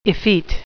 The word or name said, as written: effete